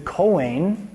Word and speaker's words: Kohen: The Hebrew is the Kohen. Everybody say Kohen.